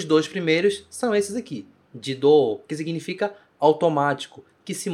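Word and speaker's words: Jido (自動): E o kanji do, que é mover, eu formo esta palavra que se pronuncia. Como será que pronuncia? Jido. Jido.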